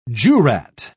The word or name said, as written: jurat